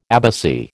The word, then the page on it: abbacy: American English